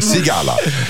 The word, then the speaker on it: Sigala: Sigala. Absolut. I Mix Megapol. jag var tvungen att dubbelkolla uttalet där och jag fick en nickning till mig. – Sigala.